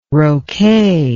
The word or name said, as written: roquet